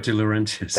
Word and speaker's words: De Laurentiis: De Laurentiis.